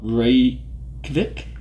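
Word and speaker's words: Reykjavík: Reykjavik? – Very good.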